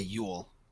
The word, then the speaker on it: Yule: I know we had an article that Dylan wrote for Thomas Jule Nielsen towards the end of last season. – It's pronounced Yule. – Okay, Yule. I like saying Jule. Jule just sounds cooler.